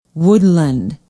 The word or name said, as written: woodland